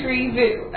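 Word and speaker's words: Tri Vu: Well, good. Well, tree, is that, that's, I'm pronouncing that correctly? – T-R-I. – Okay. – And B-U is his last name. – Tree Vu. Okay.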